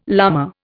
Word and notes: llama: Indian

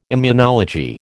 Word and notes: immunology: American English

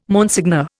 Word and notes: Monseigneur: Indian

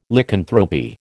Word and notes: Lycanthropy: American English

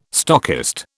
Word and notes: stockist: British English

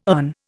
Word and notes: urn: Indian

